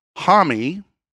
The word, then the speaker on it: Homme: So Josh Homme, that's the pronunciation of his name.